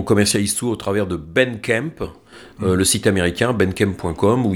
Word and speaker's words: Bandcamp: Alors pour les gens qui ne seraient pas anglophones, Bandcamp, ça s'écrit avec un A, hein, c'est Bandcamp en fait. – Bandcamp, B-A-N-D-C-A-M-P.